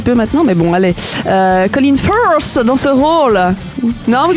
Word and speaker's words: Colin Firth: Colin Firth.